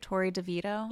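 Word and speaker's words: Torrey DeVitto: He dated, am I pronouncing this right? Tori DeVito? – Yeah, that's his ex-wife.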